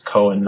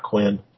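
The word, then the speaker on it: Qohen: and he's like constantly correcting him that it's Cohen, not Quinn.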